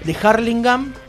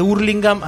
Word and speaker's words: Hurlingham: Y decía que si sos de Harlingham, andás a caballo, y si sos de Hurlingham, andás en karting. Según el deporte que vos hagas ahí en el barrio, digamos, es como se lo pronuncia.